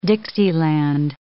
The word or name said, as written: Dixieland